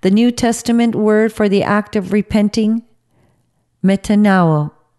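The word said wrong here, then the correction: Metanoeo (μετανοέω): I can't even pronounce it properly. Metanoeo.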